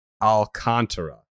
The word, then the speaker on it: Alcantara: Alcantara, right? – Yes, I just looked up the pronunciation. That styling of pronunciation, that is how it is listed here. – Sandy Alcantara.